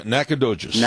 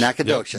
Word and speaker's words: Nacogdoches: Did I say that right? – Yeah, Nacogdoches. – Nacogdoches.